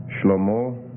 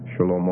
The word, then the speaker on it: Shlomo: Shlomo or Shlomo. When I learned it in Hebrew, it was with a silent shwa there, but there's some debate about how they pronounce some of these. So Shlomo or Shlomo.